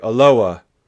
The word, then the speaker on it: Aloha: And that is Aloha, exactly how you think it's spelled. But around here, we say Aloha.